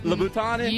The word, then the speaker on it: Louboutin: Louboutin.